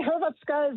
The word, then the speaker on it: Hrvatska: Hrvatska is how they say it in Croatia. They don't call their country Croatia. They call it Hrvatska.